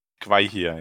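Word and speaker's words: Gwaihir: Denn er wusste natürlich schon von Gollums Flucht, denn äh, wie spricht man den aus? Gwei hier? – Gwei hier, ja.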